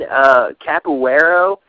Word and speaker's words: capoeira: He goes, do you mean capoeira? He goes, that's, that's what I'm being told is the correct pronunciation.